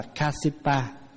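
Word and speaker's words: Kāśyapa: Pháp Hòa nói ví dụ như Ngài Ca Diếp, Ca Diếp là tiếng Phạn đọc là Kasipa.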